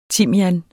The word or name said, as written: timian